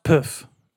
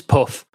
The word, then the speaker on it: poof: But I think it's pronounced poof. – It's puff. – Puff, yeah.